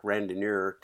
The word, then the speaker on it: Randonneur: And we're here to talk about something that's happening this fall, the Ozark Randonneur.